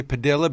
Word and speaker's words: Padilla: And I say Padilla because that is the way his name is pronounced, Jose Padilla.